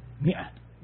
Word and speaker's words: مئة: وضعوا له إيش لها ألف إيش ما ماء لا مئة، فإن الألف لا تلفظ إذا لفظتها معناتها أنت حمت الكلام. – تقول مئة هكذا.